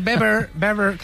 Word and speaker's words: Weber: Está Manfred Weber, que se dice Weber, no se dice Weber, ¿vale? – Sí, sí.